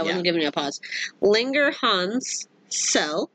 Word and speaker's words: Langerhans: Yeah, Langerhans. – Yep. – Okay, see? You were adding a syllable, but that's okay. – Anyway. – Oh, you never mind, you were just combining the two words.